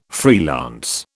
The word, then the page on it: freelance: British English